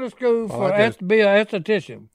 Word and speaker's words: esthetician: She is going to school to obtain her esthetician? – Yeah. – License? Did I say it right? – Mm-hmm.